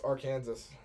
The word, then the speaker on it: Arkansas: I got all confident they were going to Alabama was going to go into Arkansas and lose. – Arkansas. – Yeah, I heard that. – Arkansas, buddy. If you spell it Arkansas, it's Arkansas. So just clear that one up.